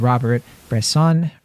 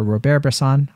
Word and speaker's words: Robert Bresson: a brilliant essay about the films of Robert Bresson, or Robert Bresson. I always pronounce it in the Anglican fashion.